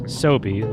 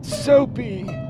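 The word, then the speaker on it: Soapy: Sobe, like the lizard drink. – Is that what lizards drink? Is Sobe? – Jefferson Sobe Smith. – I believe it's with a P. Sobe.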